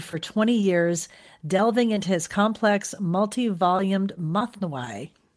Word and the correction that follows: Mathnavi: Mathnavi. Mathnavi, or some people call it, pronounce it Masnavi.